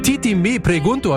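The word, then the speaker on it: Tití Me Preguntó: und mit ein bisschen Glück tanzt ihr zu Titi Me Pregunto.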